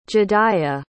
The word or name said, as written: jediah